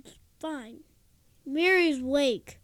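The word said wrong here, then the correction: Mirari's Wake: does... – Mirari's Wake. I keep saying it wrong.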